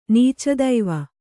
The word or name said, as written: ನೀಚದೈವ